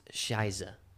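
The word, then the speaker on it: Scheiße: I don't know how to say it. It's S-C-H-E-I-S-S-E. – Hmm. – Which is Shiza.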